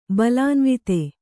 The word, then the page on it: ಬಲಾನ್ವಿತ: Kannada